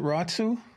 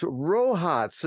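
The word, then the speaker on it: Rohatsu: Is that how you pronounce it, Ratsu? – No, Ratsu. It's Rohats, and a Merry Rohats to you too, my friend.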